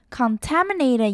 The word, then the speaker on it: contaminated: 再来呢，看到 contaminated， contaminated 也要注意到它的重音呢是在 taminated， contaminated。